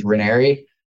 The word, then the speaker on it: Ranieri: So it's just my first name, last name, P-A-U-L, Paul. Last name, it's hard to spell and pronounce. It's Ranieri. Everybody gets it wrong, but it's R-A-N as in Nancy, I-E-R-I.